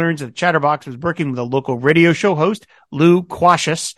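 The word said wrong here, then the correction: Lou Quacious: And it took me a second to get that. I guess I should pronounce it Luquacious.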